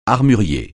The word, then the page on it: armurier: francais